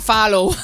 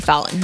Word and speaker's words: Fallon: Lo dije bien. – Jimmy sí. – Fallon. Antes yo decía Fallow. Pobrecita de mí.